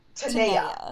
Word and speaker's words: Tanea: My brain also wants to call her Tanya, but there's an extra N in there in the first syllable. – Yes. – Which means it's Tanea. Like, it's it mentally, my brain has to, like, kind of unfuck it up in order to say it properly.